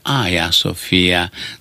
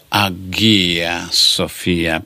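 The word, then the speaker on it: Aia Sofia (Αγία Σοφία): Ei vor zice Aia Sofia, nu vor zice Agia Sofia, pentru că e greu de pronunțat consoana în fața cuvintelor.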